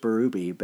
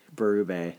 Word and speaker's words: Berube: I know it's just Berube, but I feel like Berube sounds better, so forgive me.